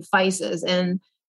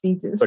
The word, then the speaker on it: Physis: But what happened that most of American people didn't know how to pronounce. And so from Physis, it became Physis. But you said it right. It's originally Physis.